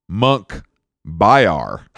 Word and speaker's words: Munkhbayar: His name, Monkey Bar. – No. Monk Bayar. Hold on. Let me spell it. Go back. For you guys at home, it's MUNKHBAYAR.